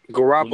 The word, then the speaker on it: Garoppolo: Garoppolo.